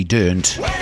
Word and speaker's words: Dirnt: Have I pronounced that right? Durnt, D-I-R-N-T, Durnt.